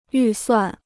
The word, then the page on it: 预算: yù suàn